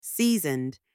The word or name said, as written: seasoned